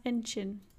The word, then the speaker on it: Incheon: I think it's pronounced Incheon. – Incheon. – Incheon. Yeah. Like, awesome. Inchn. Incheon. – Incheon. – Yeah, according to Google.